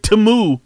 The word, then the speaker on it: Temu: Like, this should, China, I lost respect for China when they came out with that lame-ass commercial with that lame-ass jingle trying to tell us that we're going to now pronounce it Timu.